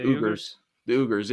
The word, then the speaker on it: Uyghurs: Exactly, at the same time, there, you know, like, also with the how do you pronounce their names? – The Uyghurs.